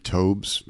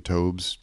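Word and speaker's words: Taubes: It wasn't until a friend actually introduced me to a book, the book called Why We Get Fat by the author Gary Tobes, I believe is how you pronounce his name, Tobes.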